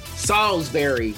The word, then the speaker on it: Salisbury: I'm Jerry Bonkowski with my good old buddy Ben White down there in, I'm going to say it right today, Salisbury, there you go.